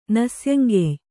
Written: ನಸ್ಯಂಗೆಯ್

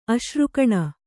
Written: ಅಶ್ರುಕಣ

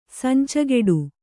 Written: ಸಂಚಗೆಡು

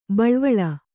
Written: ಬಳ್ವಳ

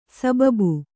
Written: ಸಬಬು

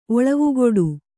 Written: ಒಳವುಗೊಡು

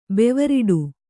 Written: ಬೆವರಟ್ಟೆ

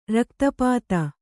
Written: ರಕ್ತಪಿತ್ತ

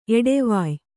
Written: ಎಡೆವಾಯ್

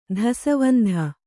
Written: ದೆಸೆವಂತ

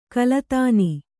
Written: ಖಲಧಾನ

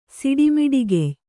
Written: ಸಿಡಿಮಿಡಿಗೆಯ್

